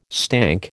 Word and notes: stank: American English

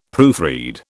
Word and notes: proofread: British English

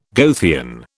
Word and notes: Goethean: British English

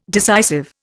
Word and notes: decisive: Indian

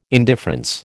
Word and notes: indifference: American English

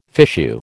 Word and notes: fichu: American English

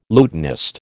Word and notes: lutanist: American English

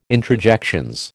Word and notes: introjections: American English

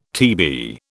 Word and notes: TB: British English

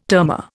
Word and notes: termor: Indian

